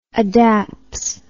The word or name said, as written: adapts